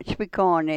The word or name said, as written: chpi kooni